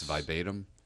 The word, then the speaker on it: verbatim: And I did learn that I've been mispronouncing verbatim. Yes. It's verbatim.